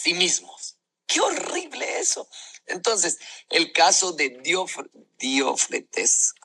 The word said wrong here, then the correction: Diótrefes: ¿cómo se pronuncia así? Diótrefes. El caso de Diótrefes es un común denominador dentro de la iglesia.